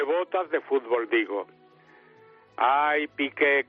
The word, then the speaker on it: Piqué: Todo suena igual, porque vamos a ver lo de Piqué, que tiene acento en la E.